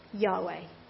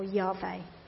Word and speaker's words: Yahweh: And he says the Hebrew letters of Lord, L-O-R-D, the Hebrew letters are Y-H-V-H, which we will recognise is pronounced Yod-Heh-Vah-Heh. Some pronounce the name Yahweh. Yeah? Or Yahweh, the name of the Lord.